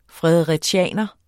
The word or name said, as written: fredericianer